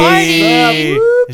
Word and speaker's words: Pardi: It was reworked into a commercial by a man named John Party, who's a modern day Party. Hey, John Party. – John Party. – It's a party when he shows up. – His last name is spelled P-A-R-D-I.